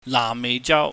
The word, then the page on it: naahmmeihjau: cantonese